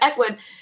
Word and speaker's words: Ecwid: They always make it so hard, but Ecwid.